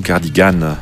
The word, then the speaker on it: cardigan: Le mot anglais qui désigne une veste de laine tricotée à manches longues et boutonnées devant, c'est le cardigan ou cardigan, si vous prononcez à l'anglaise.